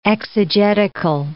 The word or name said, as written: exegetical